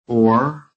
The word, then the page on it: OR: American English